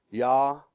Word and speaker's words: yadah: Y-A-D-A-H. Pronounced Yada. Yada.